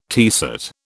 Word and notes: teaset: British English

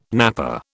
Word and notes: napper: British English

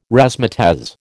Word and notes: razzmatazz: American English

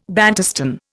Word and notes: Bantustan: Indian

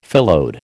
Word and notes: phyllode: American English

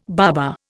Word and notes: baba: Indian

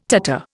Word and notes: tatter: Indian

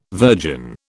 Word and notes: virgin: British English

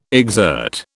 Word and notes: exert: British English

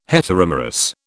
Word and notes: heteromerous: British English

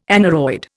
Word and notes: aneroid: Indian

